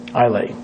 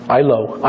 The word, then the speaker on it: Islay: I always pronounce it Islay. I was just with my friend, Rabbi Y.Y. Rubenstein, who's Scottish. He almost had a heart attack when I said Islay. Islay. Yeah. Ilo. I don't know.